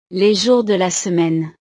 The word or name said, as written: les jours de la semaine